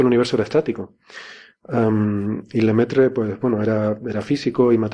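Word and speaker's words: Lemaître: sacerdote de la época que se llamaba Lemaitre, creo que se pronuncia algo así en francés. Bueno, será Lemaitre. – Lemaitre.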